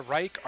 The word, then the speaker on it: Reich: That same evening in O'Shaughnessy Performance Space in Benilde's Hall on the College of Santa Fe campus, Martha Reich, R-E-I-C-H, is that how you pronounce it? – Yep. – And Michael Kott, K-O-T-T, which rhymes with not, N-O-T-T, who's kind of hot, H-O-T.